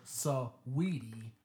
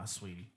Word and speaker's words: Saweetie: Female rapper Saweetie. – Is that how you say her name? – Nah, Sweetie.